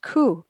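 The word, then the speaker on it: ku (く): In Japan, the number 9 is pronounced ku.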